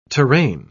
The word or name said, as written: terrane